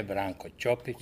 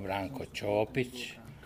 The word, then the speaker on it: Ćopić: Red je da ovom prilikom, kad govorimo o Branku Ćopiću, da pravilno izgovaramo to njegovo prezime. On nije Branko Ćopić, nego Branko Ćopić.